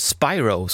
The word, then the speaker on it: Spiro: Sienna Spiro, nej förlåt, Spyro ska det uttalas.